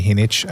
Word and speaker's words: Jinich: I'm a big fan of Patty Hinnich. Her last name is spelled J-I-N-I-C-H.